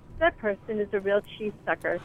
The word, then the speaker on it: Qi: Qi is energy in Chinese. – Qi, it's spelled Qi, and sucker is sucker.